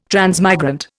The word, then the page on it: transmigrant: Indian